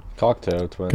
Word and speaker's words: Cocteau Twins: Cocteau Twins.